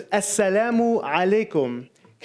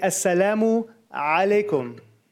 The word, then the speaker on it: Assalamu alaikum: So let's try it one more time. Assalamu, and you're going to go back deep in here. Alaikum, you know that ah sound, like Pee Wee Herman, right?